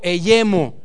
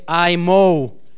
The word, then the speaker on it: Eyemo: Como colirio eyemo. Viene eyemo de I en inglés. I mo. Sería la pronunciación correcta del colirio.